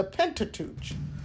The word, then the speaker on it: Pentateuch: The first section is the Pentateuch, and that is spelled P-E-N-T-A-T-E-U-C-H, Pentateuch.